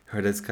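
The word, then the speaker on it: Hradecká: I used to get so annoyed when people would call Hradecka and Hlavačkova the silent H's because the H's are not silent in their names. Like, you say the H...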